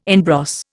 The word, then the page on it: en brosse: Indian